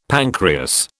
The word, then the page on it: pancreas: British English